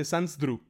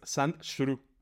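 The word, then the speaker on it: Sandshrew: Es que la gente dice Sans pero es Sans shuru O sea, se escribe Sans shuru Y todo el mundo dice Sans Yo también digo Sans Pero no, o sea.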